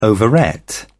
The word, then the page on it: overate: British English